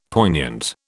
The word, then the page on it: poignant: British English